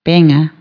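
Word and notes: ołówek: Polish

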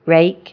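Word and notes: angazo: Galician

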